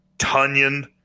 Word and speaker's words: Tonyan: Um, Tonyan rhymes with Funyon will be 100%.